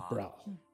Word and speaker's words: Brawl: Brawl. Like you've got something Brawl. Stuck in the back of your throat is the best way to pronounce it.